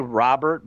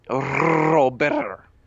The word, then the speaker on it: Robert: How do we pronounce it? – They say Robert, but like, I talked to him personally, okay, and he told me it's Luis Robert.